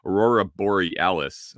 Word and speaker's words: Aurora Borealis: I think that Aurora Borealis, yeah, I said that right. Aurora Borealis was being visible down into like northern Nebraska or something.